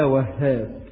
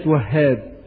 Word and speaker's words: الوهاب: ما معنى كلمة وهاب؟ بعض الناس بينطق هذا الاسم خطأ، يقول لك الحاج عبد الوهاب، وهاب، وهاب، مش اسمه وهاب، اسمه وهاب، فلا تقل عبد الوهاب، قل عبد الوهاب.